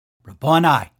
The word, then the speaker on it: Rabboni: Now, you'll hear, and I have heard people pronounce that differently, and, you know, I'm not going to get hung up on that. I say it Rabboni, and maybe somebody else says it differently.